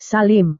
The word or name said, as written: salim